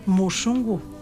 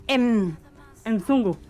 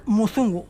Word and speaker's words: mzungu: Porque claro, se escribe MZUNGU. Y así yo leería Enzungu. – Enzungu. Y me ha dicho ya, no, se lee Muzungu.